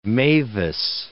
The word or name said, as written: mavis